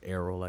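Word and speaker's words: Errol: Oh, I think I said Earl. – Arrow. It's pronounced Arrow, I guess.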